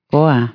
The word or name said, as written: sevinmek